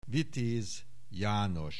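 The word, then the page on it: Vitez Janos: Hungarian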